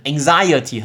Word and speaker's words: anxiety: 那名词念成 anxiety，很特别哦，因因为它的重音不一样，所以它的用呃念法也不同。